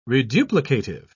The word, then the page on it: reduplicative: British English